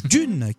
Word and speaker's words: DVNE: Dune, qui s'écrit D-V-N-E, mais qui se prononce bien comme l'œuvre de Frank Herbert.